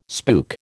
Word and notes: spook: American English

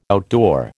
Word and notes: outdoor: American English

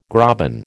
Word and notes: graben: American English

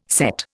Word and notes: sett: Indian

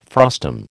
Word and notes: frustum: American English

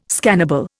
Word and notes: scannable: Indian

